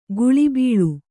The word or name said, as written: ಕುಳಿಬೀಳು